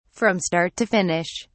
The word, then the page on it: from start to finish: British English